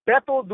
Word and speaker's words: Beto: It's Beto Duran, right? That's how you say it. But I know that people can't say Beto, so they say Beto.